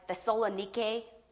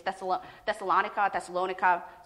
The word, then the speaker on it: Thessalonica: But so the correct pronunciation is something like Thessalonike, okay? But, you know, but for the sake of it, just rolls off the tongue better. So just know in the English, I think pronunciation is Thessalonica.